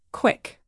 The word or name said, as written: quick